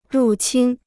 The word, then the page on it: 入侵: rù qīn